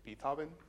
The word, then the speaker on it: Beethoven: Am I saying that correctly? Beethoven, thank you.